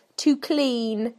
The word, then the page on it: to clean: British English